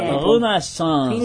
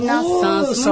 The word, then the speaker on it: Renaissance: Olha, olha a pronúncia, hein. É Aliança Francesa. – É. Renaissance. – Renaissance. Muito bem.